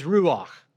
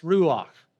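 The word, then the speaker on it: ruach (רוּחַ): The Hebrew word is ruach. We would spell it in English, R-U-A-H, but it is pronounced ruach.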